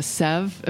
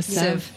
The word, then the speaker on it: sieve: And a strain, like a sieve. Am I saying that right? A sieve.